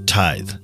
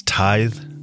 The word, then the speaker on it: tithe: I mispronounced the word tithe in the last episode. It's tithe, not tith.